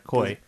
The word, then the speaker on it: Koei: Koi, the makers, Koi, the, I think I'm pronouncing that correct, Koi. – K-O-E-I? – E-I, yeah.